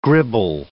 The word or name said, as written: gribble